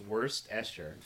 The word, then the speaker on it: Worcestershire: It's pronounced Worcestershire. – This is an English word, so looking at it, you can't tell how it's said.